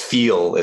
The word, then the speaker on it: FIIL: My wife lost her one of her AirPods, and I just said, hey, I'm testing out these Feel, it's spelled F-I-I-L, you know, it's a Chinese brand, that sound pretty good and, you know, pair really nicely.